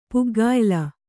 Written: ಪುಗ್ಗಾಯಿಲ

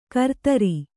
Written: ಗಿರಿಧರ

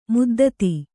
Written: ಮದ್ದತ್ತು

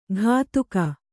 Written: ಘಾತುಕ

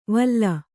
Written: ವಲ

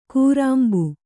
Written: ಕೂರಾಂಬು

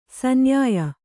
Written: ಸನ್ಯಾಯ

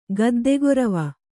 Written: ಗದ್ದೆಗೊರವ

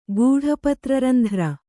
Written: ಗೂಢಪತ್ರರಂಧ್ರ

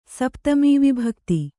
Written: ಸಪ್ತಮೀವಿಭಕ್ತಿ

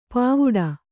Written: ಪಾವುಡ

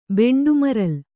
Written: ಬೆಂಡುಮರಲ್

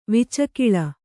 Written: ವಿಚಕಿಲ

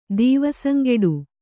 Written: ದೀವಸಂಗೆಡು

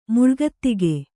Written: ಮುಳ್ಗತ್ತಿಗೆ

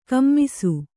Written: ಕುಮ್ಮಿಸು

